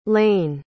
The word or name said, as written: layne